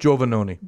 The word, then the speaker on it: Giovannoni: Did I say that? – No, right? – Not at all. – How do you say it? – Giovannoni. – Giovannoni. – Yeah. My grandmother would say that it's pronounced the way it's spelled.